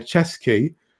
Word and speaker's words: Ceschi: Until next time, we'll be reviewing Sad Fat Luck by Chesky. And yes, I have it written phonetically in my notes, because it's spelled C-E-S-C-H-I.